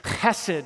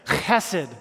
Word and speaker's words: hesed: And if you say it correctly, it's hesed. if you ever hear Hasidic Jews talk, there's like a grovel in the throat when you're getting ready to spit. Like, sounds like this, hesed. That's how you say it.